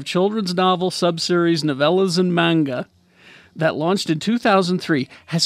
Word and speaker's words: manga: It's manga. – Manga? – I've been saying manja. – Manja? That's something different. – What the fuck is manja? – That's coming to Nevada soon. – No, that's just weed. – It's ganja. – Ganja. – Yeah, well, what the fuck is manja then? Why am I saying it right? – You're saying it wrong.